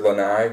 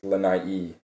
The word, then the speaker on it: Lanai: So almost anytime there's a double vowel, there's two syllables there. So like lanai, the word lanai, which I think means like balcony or porch, but it's also an island there. – Mm-hmm. – Yep. – It should actually be pronounced lanai-e.